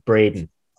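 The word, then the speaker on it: Bredin: Yeah, my surname is tricky because everyone either, if they read it first, they say it wrong. And if they hear it first, they spell it wrong. So it's B-R-E-D-I-N and it's pronounced Breden.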